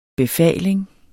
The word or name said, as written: befaling